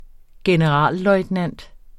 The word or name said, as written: generalløjtnant